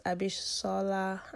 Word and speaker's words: Abishola: I know it's pronounced Abishola. I'm not sure where the H is from, but I'll go with it.